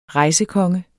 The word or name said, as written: rejsekonge